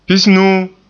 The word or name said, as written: pisnuu